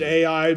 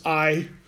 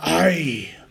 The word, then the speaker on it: Ai: Some people pronounce it Ai, but I looked it up so that I would know it's Ai. And if you want to, put an accent on it, Ai.